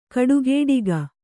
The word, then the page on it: ಕಟುಗೊಡಗಿ: kaṭugoḍagi